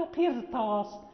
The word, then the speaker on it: قرطاس: يعني هنا لا نقول قرطاس، قرطاس، يعني الراء ننطق بها نعم مفخمة،